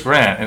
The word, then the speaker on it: Grant: My name's Chris Grant, and it rhymes with Chris Plant.